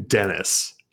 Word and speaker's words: Denys: I'm saying Dennis, even though I want to say Denise or Denies, because that's how it's spelled, D-E-N-Y-S, but Dennis Bondar, -170.